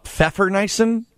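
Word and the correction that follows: Pfeffernüsse: Pfeffernusse. – P-F, am I wrong yet? – No. – Oh, P-F-E-I-N-U-S-S-U with the umlaut E-R? – Oh, you're awfully close.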